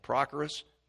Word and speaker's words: Prochorus: But I want you to understand, Prochorus, you see the accent is on the I.